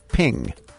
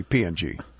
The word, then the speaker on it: PNG: The creators of the PNG image file format wanted to be wanted it to be pronounced ping. But there's no I in it, so people just say PNG.